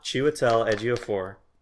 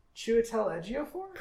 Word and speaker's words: Chiwetel Ejiofor: I want Chiwetel Ejiofor. Say his name. – What? – Say his name. – Say whose name? – Chiwetel Ejiofor. – Chiwetel Ejiofor? – Perfect, you did it.